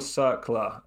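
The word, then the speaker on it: Cercle: It's called Circle. You pronounce it Circle. – Okay. – You pronounce it Circle. So Circle Bruges.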